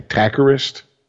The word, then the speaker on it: Takkarist: T-A-K-K-A-R-I-S-T. That's his first name. Takarist is, I guess, how you pronounce that? – Sure.